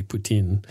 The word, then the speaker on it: poutine: And I know it's actually poutine, but I want to avoid being accused of being a Russian sympathizer because it sounds an awful lot like Putin.